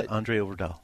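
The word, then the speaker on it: Øvredal: Well, thankfully, is it André Overdahl? Is that how you pronounce it? – Yeah, André Overdahl.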